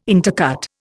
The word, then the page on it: intercut: Indian